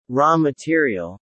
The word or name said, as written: raw material